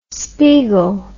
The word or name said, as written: spiegel